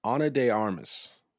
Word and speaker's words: Ana de Armas: It was Ana de Armas.